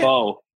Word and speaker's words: faux: Oh, how do I say it? – Faux. – Faux. – What does the faux say? – Like French. The noodles? – Like faux pas.